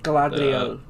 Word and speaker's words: Galadriel: Correct me if I'm wrong, but it's Galadriel? Is that how you say her name? – Galadriel.